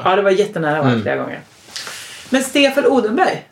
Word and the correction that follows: Odelberg: Jag säger Stefan Odenberg. För jag tycker det är för svårt. Han är Stefan Odelberg. – Mm. – Det tycker jag är ett jättesvårt namn att uttala.